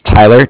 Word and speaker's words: Tyler: My name is Tyler, T-Y-L-E-R. – Uh-huh. – And I'm calling with Cardiff. It's spelled C-A-R-D-I-F-F, as in Frank Frank.